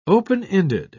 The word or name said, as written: open-ended